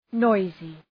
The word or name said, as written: noisy